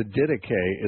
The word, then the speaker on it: Didache: It's spelled exactly like two English words, the word did and the word ache. If you put the two English words did and ache together, you'd have Didache.